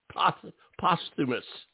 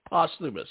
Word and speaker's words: Posthumus: Posthumus. Posthumus, right? – Posthumus, yep.